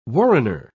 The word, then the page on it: warrener: British English